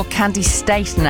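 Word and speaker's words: Candi Staton: Or Candy Staton, actually. That's how she pronounces it, and she should know.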